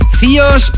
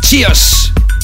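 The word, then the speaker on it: CIOZ: no sabía si se pronunciaba Cios o Sayos o Kios, pues nada de eso. Se escribe C-I-O-Z y se pronuncia Chios, como si fuera TX, Chios.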